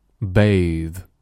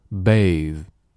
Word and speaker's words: bathe: Bathe. Bathe. – 要注意这里的 E 不发音，最后的 TH 发的是非常轻的 bathe，就一个辅音，千万不要拖音，拖音就变成了我们汉语中晚上睡觉盖的被子，不是，是 bathe.